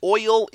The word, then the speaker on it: oil: And since I know y'all will read this, oil is one syllable. It's a diphthong like way. It's not way.